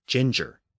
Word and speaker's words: ginger: But note that the modern form of the word is ginger, pronounced with soft Gs, which is a French pronunciation. The Latin pronunciation would have used hard Gs.